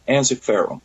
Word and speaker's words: Ensiferum: Is it Ensiferum? Ensiferum? How do you pr- prefer to pronounce it? – We pronounce it Ensiferum. Ensiferum. Okay.